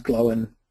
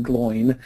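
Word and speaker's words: Glóin: I mean, they pronounce Glowin properly. They don't pronounce him Gloin.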